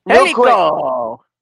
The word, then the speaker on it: Angelico: You guys pronounce the name. – Angelico! – Angelico. Yeah, it's an Angelico or Angelico, either or.